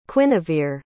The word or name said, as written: quinevere